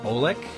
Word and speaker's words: Oleck: One's called Hang 'em High by Jack, I believe the last name is pronounced Olek. That's how I'm going to pronounce it anyway. O-L-E-C-K.